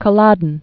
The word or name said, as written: Culloden Moor